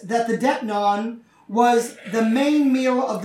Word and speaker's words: deipnon: He says, I'll come in and I will eat a specific meal known as Depnon. It's spelled D-E-I-P-N-O-N, Depnon.